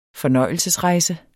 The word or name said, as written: fornøjelsesrejse